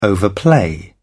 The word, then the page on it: overplay: British English